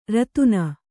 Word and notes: ರೋದನಿ: rōdani

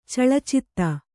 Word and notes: ಚಲಚಿತ್ತೆ: calacitte

